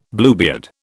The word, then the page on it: Bluebeard: British English